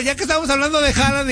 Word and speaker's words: Haaland: Dicen que su pronunciación es Haaland.